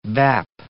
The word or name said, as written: bap